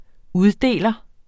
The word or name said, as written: uddeler